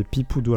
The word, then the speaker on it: Peepoodo: Et, du coup, euh, c'est quoi leur site Alors, le site, euh, ben, c'est vous tapez Pipoudou. Alors, ça s'écrit p e e p o o d -O.